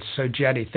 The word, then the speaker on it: Sogeti: It's Sogeti. Think of jet versus the G. – Sogeti.